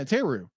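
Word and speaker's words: Therru: How do you pronounce her name? – Teru? – Yeah, Teru.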